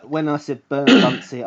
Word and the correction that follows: Burnsy: I mean Burnsy. – Burnsy is in League One. Sorry, I mispronounced that.